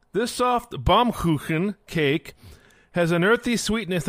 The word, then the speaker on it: Baumkuchen: Baumkuchen. Baumkuchen.